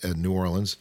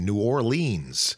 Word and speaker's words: New Orleans: you can tell you're not a local because you were saying New Orleans. And I was told that you shouldn't say it like that, New Orleans.